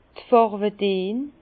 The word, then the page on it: d' Farwedeen: Bas-Rhin; Herrlisheim